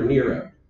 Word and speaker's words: Nero: And then, of course, there's the one about the Emperor Nero, but I can't really say anything else about it. Because rhymes with zero.